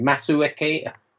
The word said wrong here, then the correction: Masuaku: What's his name? – Masuaku. – Masuaku.